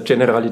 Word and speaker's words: Generali: wir uns unsicher waren, ob es der äh, Generali oder Generali Tennisstarter ist. Äh, ich glaube, wir haben, oder Achim war sich eher sicher, dass ich es nicht korrekt ausspreche, sondern der Generali ist. Oder wie könnt ihr mir als Profis das sagen? – Ja, genau, richtig. Also es ist der Generali Tennisstarter. – Der Generali Tennisstarter, perfekt, genau.